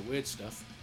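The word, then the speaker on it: Wyrdstæf: Ian plays electronics and weird noises in a awesome paleolithic black metal band, Weird Stuff, which is spelt WYRDST weird AE thingy F.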